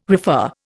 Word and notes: refer: Indian

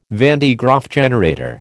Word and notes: van de Graaff generator: American English

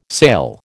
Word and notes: sale: American English

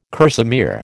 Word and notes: kerseymere: American English

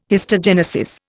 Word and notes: histogenesis: Indian

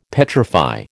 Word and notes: petrify: American English

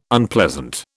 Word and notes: Unpleasant: British English

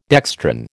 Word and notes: dextrin: American English

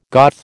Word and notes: Goth: American English

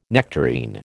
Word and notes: nectarine: American English

